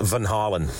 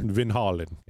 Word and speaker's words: Van Halen: Apparently, it should be pronounced Van Halen. – Just like Kelsey said, Van Halen.